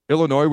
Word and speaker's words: Illinois: They've got West Virginia, the mighty Delaware, Illinois, where the S is silent, Iowa, Northwestern, UMass.